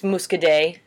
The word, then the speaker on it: Muscadet: It's Muscadet. You wouldn't pronounce the T on the end.